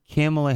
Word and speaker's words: Kamala: So how old is Kamala Harris? – She's, I think she's... – And did I say it right? – Kamala.